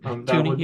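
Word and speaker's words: Toney: I think without Tooney, that or Tony, however you pronounce it, Tooney, you had it.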